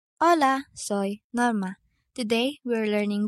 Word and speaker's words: hola: Letter H is always silent. For example, hola, hola.